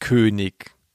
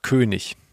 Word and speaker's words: König: man sagt ja nicht König, man sagt König. Wenn da kein, wenn das ein am Wortende ist, dann wird das GCH ausgesprochen.